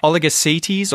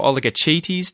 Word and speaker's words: oligochaetes: So, for the science nerds listening, oligocetes or oligochaetes, depending on how you pronounce the Latin word there, is the subclass of organisms that includes earthworms, worms that are found in the ground.